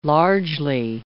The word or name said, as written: largely